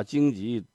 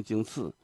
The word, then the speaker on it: 荆棘: 玫瑰玫瑰我爱你，把荆棘念成荆刺，到现在还有人念呢。